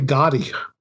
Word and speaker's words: Gotti: He starts off talking about how Gotti, am I saying that right? Gotti? – I say Gotti. – Gotti? Okay. – Yeah, just Gotti.